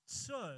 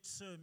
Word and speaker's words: sur: It's sur, it's pronounced sur, like in California they have the big sur, meaning the big surf. Well, in Hebrew it's sur.